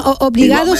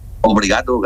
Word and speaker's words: obrigado: ¿Cómo, obligado, sí, ¿cómo se dice? Obligado es gracia, ¿no? – Obrigado, gracias.